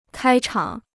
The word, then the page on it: 开场: kāi chǎng